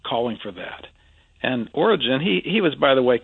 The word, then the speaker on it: Origen: I believe the famous church father, I almost hesitate to mention this, Origen, and that's how you pronounce it, Dave, I checked.